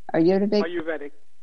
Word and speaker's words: Ayurvedic: What is it? Ayurvedic. How do you say that? – Ayurvedic.